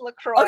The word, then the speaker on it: LaCroix: I think it's pronounced LaCroix. Forgive me. – It is pronounced LaCroix.